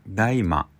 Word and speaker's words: DAIMA: うんえー、鳥山明さんは「ドラゴンボール大魔」というタイトルについて「大魔」えー、 DAIMA 大文字で DAIMA と書いて「大魔」なんですけど「えー、大魔」というのは造語で漢字では「大魔」えー、大魔